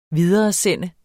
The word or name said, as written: videresende